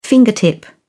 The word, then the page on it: fingertip: British English